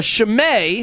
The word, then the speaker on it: Chimay: A Chimay.